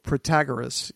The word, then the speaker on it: Protagoras: So Protagoras, you pronounce his name.